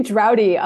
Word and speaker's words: Durade: How do you say your last name? – It's actually Drowdy.